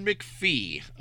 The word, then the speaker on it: McFee: John McVie, M-C-F-E-E.